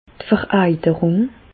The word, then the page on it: d' Vereiterùng: Bas Rhin; Reichshoffen